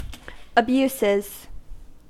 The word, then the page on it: abuses: American English